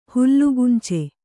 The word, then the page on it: ಹುಲ್ಲುಗುಂಚೆ: hullu gunce